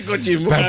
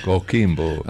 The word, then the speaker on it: Coquimbo: Coquimbo.